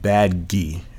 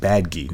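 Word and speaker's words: Badguy: Bad Guy. It's pronounced Bad Guy, not Bad Guy, because it's European or French or whatever.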